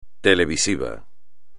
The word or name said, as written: televisivo